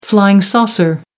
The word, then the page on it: flying saucer: American English